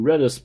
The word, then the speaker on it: Redis: Anywho, Tlake, enunciation 2, Redis pronunciation. Most people pronounce Redis, not Redis.